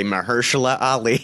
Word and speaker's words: Mahershala Ali: No, you say normally. Typically, you would say Mahershala Ali.